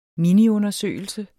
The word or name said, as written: miniundersøgelse